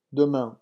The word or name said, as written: demain